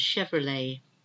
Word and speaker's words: Chevrolet: B-E-R-E-T. Chevrolet, the car, the American car, Chevrolet. It doesn't have the T pronounced at the very end, Chevrolet. A depot is where you are stored, like a warehouse, depot, D-E-P-O-T.